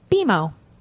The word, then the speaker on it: BMO: But anyways, now it stands, according to Wikipedia, and now it stands for nothing, and it's pronounced BMO.